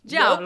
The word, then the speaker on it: giallo: It's spelled G-I-A-L-L-O. And I was like, Giallo. – Giallo. – Nope, it's Giallo. – Giallo. – Or like J-A-H-L-O.